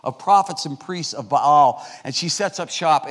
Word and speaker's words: Baal: And she was a fanatical devotee to the Canaanite god Baal, or to be pronounced correctly, Baal. So, Baal. There should be like a little hyphen in there between the A's.